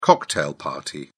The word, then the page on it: cocktail party: British English